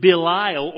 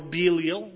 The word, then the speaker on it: Belial: And what concord hath Christ with Belial, or Belial, depending on how you pronounce that, is a proper name for Satan.